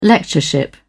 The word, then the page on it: lectureship: British English